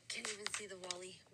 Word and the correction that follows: woolly: It's woolly mammoth.